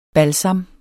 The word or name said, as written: BALSAMMENES